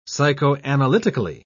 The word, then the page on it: psychoanalytically: British English